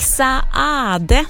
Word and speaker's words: Saade: Han stavar ju S-A-A-D-E, och då ska man tydligen uttala båda A-N också, så då gör jag det.